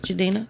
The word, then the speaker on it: Jidenna: Jidenna? – Yeah, that boy nice.